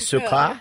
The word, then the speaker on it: Sarkar: So how do you pronounce it in French? – Sarkar. – Like sugar, okay. Actress Piyu Sarkar. Sarkar.